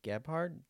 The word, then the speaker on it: Gebhard: This is episode 3, and I am here with Jeannie Gebhard.